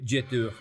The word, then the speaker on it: Jetur: Jetur. Jetur. – Ay Dios. – ¿eh? Jetur, Jetur. Que es una marca china, la pronunciamos como francesa, pero china.